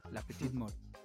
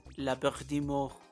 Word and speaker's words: la petite mort: Y perdónenme todos aquellos que sí hablan francés, yo no sé, pero creo que así se pronuncia, la petite mort. O la muerte pequeña. – No, oye, te faltó el acento, es la petite mort.